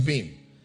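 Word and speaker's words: bin (בין): The word understanding is the Hebrew word bin, B-I-Y-N, pronounced as bin.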